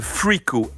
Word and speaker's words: Friko: un jeune groupe de Chicago qui s'appelle Freako, F-R-I-K-O.